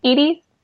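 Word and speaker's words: Edy's: Is it Eddie's or Edie's? – Edie's.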